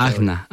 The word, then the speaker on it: Ajna: Se escribe A-J-N-A, pero tú la pronunciaste diferente. – Agna.